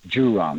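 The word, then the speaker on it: Zhurong: He swears it's a J, a weird J, just like the J on Jezero. But it's Jurong, not a...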